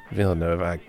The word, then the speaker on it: Villeneuve: Yeah, get some things off my chest about Villeneuve. Or how do you say it? – Villeneuve.